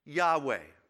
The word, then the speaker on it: Yahweh: Y-A-H-W-E-H, and it gets pronounced as Yahweh. That's our English pronunciation of it, Yahweh.